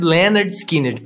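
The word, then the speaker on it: Lynyrd Skynyrd: Só que é, o nome, na moral mesmo, é Pronounced Leonard Skinner.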